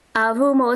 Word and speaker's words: Avumo: Avumo is spelled A-V-U-M-O.